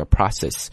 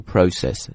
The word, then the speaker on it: process: process 这个词的意思表示过程，注意一下这个单词的读音啊，当然你可以读成美式英语叫 process，那英式英语呢读 process known as autophagy。